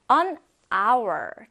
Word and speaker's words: hour: 자, 지금 선생님이 이 hour이라는 단어 어떻게 말했죠? 아 발음했죠? 아. 쌤, 분명 생긴 거는 h인데요? 자음인데요? 근데 지금 선생님이 아라고 발음했잖아요. 모음 발음입니다. 그래서 an hour이라고 하는 거예요.